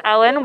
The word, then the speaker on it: Ellyn: My name is Ellyn Webb, and it's spelled E L L Y N W E B B.